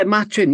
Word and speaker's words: Machin: Uh, Luke, is it pronounced Machin? Machin? I think so. Yeah, Machin. Yeah, yeah.